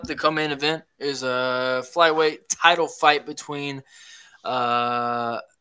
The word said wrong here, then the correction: Alexandre Pantoja: Is that how you say it? – Alexandre Pantoja.